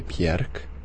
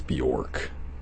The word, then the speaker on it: Björk: If we're gonna try and pronounce things correctly, that song was by Björk. Known to the general American public as Bjork.